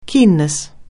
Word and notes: keenness: anglais

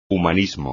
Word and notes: humanismo: espagnol

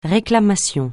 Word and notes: réclamation: francais

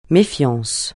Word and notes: méfiance: francais

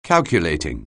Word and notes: calculating: anglais

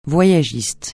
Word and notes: voyagiste: francais

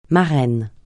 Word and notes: marraine: francais

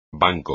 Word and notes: banco: espagnol

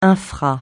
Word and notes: infra: francais

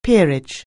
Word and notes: peerage: anglais

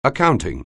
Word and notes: accounting: anglais

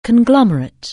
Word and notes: conglomerate: anglais

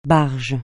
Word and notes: barge: francais

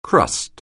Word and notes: crust: anglais

